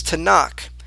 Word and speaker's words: Tanakh: and the way we pronounce the acronym is Tanakh, which is a reference to the Hebrew scriptures.